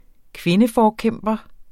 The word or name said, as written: kvindeforkæmper